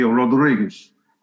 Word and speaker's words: Rodrigues: The Portuguese pronounce Rodriguez, R-O-D-R-I-G-U-E-S. Wherever you see a Z in Spanish, Gomez is Gomes in Portuguese. Sanchez would be Sanchez in Portuguese. Rodriguez would be Rodriguez.